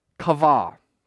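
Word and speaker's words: kavah (קוה): So this word is called kavah. Kavah. And it's spelled Q-A-V-A-H. Kavah.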